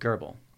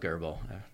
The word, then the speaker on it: Grbl: G-R-B-L is the acronym. – I've got on good authority that it's pronounced every one of those ways. – Oh, okay. Like Kaiken. – Yeah, exactly. – Yeah. – And I call it Gerbil.